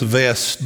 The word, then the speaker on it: West: Kenneth West, actually his last name, it looks like West with a W, but it's pronounced Vest.